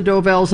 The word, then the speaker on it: Dovells: Del Forest. I'm not saying it right. – That's all right. You know it's the Dovells, and I'll give it to you for that.